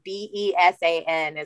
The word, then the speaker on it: besan: But if you don't see chickpea flour, Baisan, Besan is how it's pronounced.